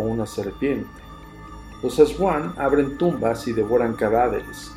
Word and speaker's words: Aswang: Se escribe así, A-S-W-A-N-G, Aswang o Aswang.